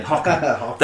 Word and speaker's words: Harkonnen: That's Harkonnen. What I was thinking. – You're pronouncing it better than me. Harkonnen.